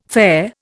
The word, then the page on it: fare: Indian